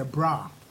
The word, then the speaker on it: bra: We don't pronounce brother as such when we are telling the story. We say bra.